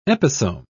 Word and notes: episome: British English

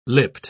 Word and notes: lipped: British English

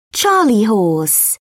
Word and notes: charley horse: British English

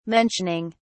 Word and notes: mentioning: British English

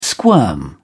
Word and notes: squirm: British English

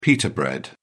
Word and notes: pitta: British English

